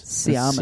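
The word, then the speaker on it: Tsiamis: Siamis. – Siamis. – Mm. – The good old Chris, the old silent letter.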